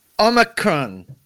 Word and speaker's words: Omicron: Questions like, how does one pronounce Omicron? – Omicron. Thank you. – You have got it right there.